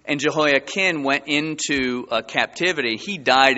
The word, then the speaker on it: Jehoiakim: It's not Kim, it's Kin. Jehoiakim went into the captivity.